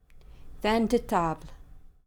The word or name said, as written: Vin de Table